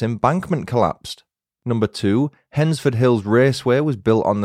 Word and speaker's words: Hednesford: Here are five quickfire facts about Hensford, which is spelled H-E-D-N-E-S-F-O-R-D. There's a lot of silent letters in Hensford.